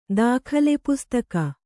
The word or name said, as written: ದಾಖಲೆಪುಸ್ತಕ